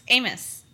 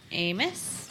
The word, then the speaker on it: Amos: It's Amos. – Tori Aimless. – Amos? – Uh-huh.